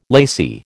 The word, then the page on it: lacy: American English